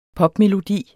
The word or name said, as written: popmelodi